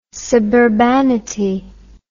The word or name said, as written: suburbanity